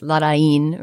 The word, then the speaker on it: Larraín: And if you did a little bit of research into what the accent means, you could figure out that it's Larraín, right, with that accent on the end.